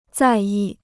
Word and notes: 在意: zài yì

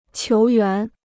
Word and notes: 球员: qiú yuán

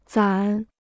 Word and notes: 攒: cuán

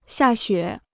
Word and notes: 下雪: xià xuě

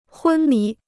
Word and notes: 昏迷: hūn mí

